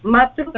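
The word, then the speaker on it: मातृ: So we pronounce it Matru and Pitru, right? – Matru, Pitru, Matre, Pitre.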